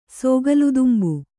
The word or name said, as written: ಸೋಗಿಲುತುಂಬು